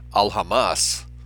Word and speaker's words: Aljamas: In Spain, prior to the expulsion of 1492, there was a strong separation between the Jewish Alhamas, usually in Spanish the second to last syllable is stressed, A-L-J-A-M-A-S.